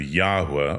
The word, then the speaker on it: Yahweh: Modern scholarship has settled on the pronunciation of Yahweh or something similar,